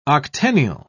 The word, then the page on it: octennial: British English